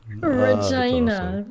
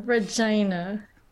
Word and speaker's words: Regina: Just remember, it rhymes with fun. – It completely rhymes with fun. – So much editing to do this week. And I did it to myself. – Regina. Regina.